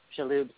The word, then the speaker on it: Shaloub: His mother and I are like, her name is Hala Madi Shalab. Shaloub, sorry, I said his name wrong.